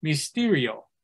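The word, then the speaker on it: mysterio (μυστήριο): And in the Eastern Orthodox churches, the word for ordinance or sacrament is this word in modern Greek. It's pronounced mysterio, but we're talking about the same thing here.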